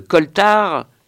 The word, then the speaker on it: coaltar: Être dans le coltard, ça s'écrit C-O-A-L-T-A-R, mais se prononce coltard, c'est un peu plus inquiétant que d'être dans le cirage ou dans les vapes, et beaucoup plus que d'être dans le brouillard.